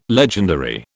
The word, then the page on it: legendary: British English